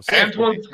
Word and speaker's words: Antuan: No, Antuan's good. Yeah, Antuan's good. As you know, it's one of the great things about Spanish, because my name is the Spanish Antuan, is things are pronounced the way they are written.